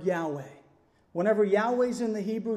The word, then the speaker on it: Yahweh: They've actually put vowels in, A and E, so that you can say Yahweh.